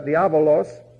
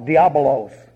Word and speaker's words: Diabolos: And you'll notice that Diabolos, it's not Diabolos. There's a V in there.